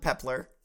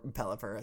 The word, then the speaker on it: Pelipper: It's not Pepler. Or Pelipper, as it's correctly pronounced.